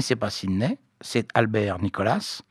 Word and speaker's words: Nicholas: Bon, Nicolas ou Nicolas, hein, on peut les prononcer à la française, c'est le nom français.